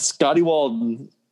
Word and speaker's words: Scotty Walden: Speaking of which, Scotty Waldron gone. – Okay, get his name right first, at least. – No. – Yes. – He has to make a name for himself. – Scotty Walden.